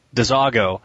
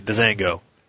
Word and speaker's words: DeZago: DeZago is the guy's name, and I keep pronouncing it DeZango.